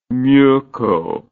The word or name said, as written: meukow